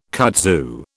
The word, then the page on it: kudzu: British English